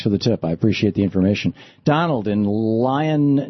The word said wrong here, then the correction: Ilion: New York, or Ilion, New York. Is that it, Donald? Am I saying that right? – Ilion, New York. You got it.